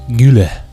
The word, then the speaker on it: Güler: Creo que Arda es Arda Güler.